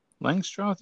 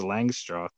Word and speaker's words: Langstroth: They say Langstroth. – Yeah. – I think it's just Langstroth. That's how I say it. Okay. That's how it's spelled.